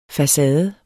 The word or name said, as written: facade